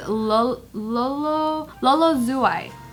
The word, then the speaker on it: Lolo Zouai: Her name is Lolo Zouai. But it's like spelled like French.